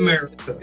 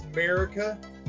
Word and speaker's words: Merrikah: This one's, that is America. – It says America. And it's an Instagram comic. I think it's, if I'm not mistaken, America_comic. – Okay. – And it's spelled MERRIKAH.